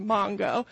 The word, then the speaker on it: Mango: And I'm up in the northern part in a town called Mongo, spelled like mango, pronounced Mongo.